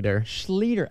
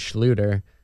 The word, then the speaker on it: Schlueter: Well, it's actually the correct German pronunciation is Schlueter. And then one of my relatives just switched it up a few years ago. So Schlueter is technically, you know, you're saying it correct. If we were in German, all the Germans would call us Schlueter, but someone changed it.